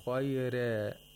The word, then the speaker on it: خَيْرَ: Warsh pronounces the ra with tarqiq, while Hafs pronounces it with tafkhim. خَيْرَ, خَيْرَ, رَ, خَيْرَ. Why is it that Warsh pronounces it with tarqiq? It's because it's preceded by a ya sakina.